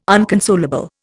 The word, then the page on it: unconsolable: Indian